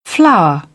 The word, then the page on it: flower: British English; əɹ